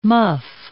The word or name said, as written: muff